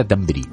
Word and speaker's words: Thunberg: No, perdona, lo has escrito mal entonces, porque nos lo explicó la propia Greta Thunberg, que se dice Greta Thunberg. Thunberg. Greta Thunberg.